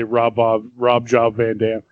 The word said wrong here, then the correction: Jean-Claude Van Damme: Jean-Claude Van Damme.